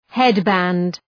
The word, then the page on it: headband: ‘hed,bænd